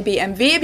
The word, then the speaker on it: BMW: In English, people say BMW. In German, we say BMW because W is pronounced W in German.